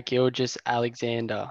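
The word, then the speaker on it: Gilgeous-Alexander: Is it Shai Gilgeous Alexander? – Okay.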